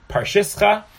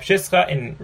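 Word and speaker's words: Przysucha: Parshischa. But this is what we would call pshischa. In Poland, they throw R's in where they're not supposed to be pronounced. So here too, there's a resh where it's not supposed to be pronounced. At least not culturally pronounced.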